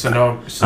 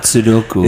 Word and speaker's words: Sunoco: Is that the official pronunciation? – Yes. Sunoco. – I'm pretty sure it's Sunoco. – It's not Sudoku.